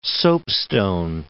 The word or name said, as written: soapstone